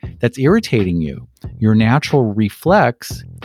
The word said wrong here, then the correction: reflex: why did I put the emphasis on the wrong syllable? It's really reflex.